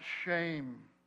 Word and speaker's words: Shem: Shem's name could easily be pronounced shame.